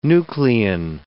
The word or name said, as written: nuclein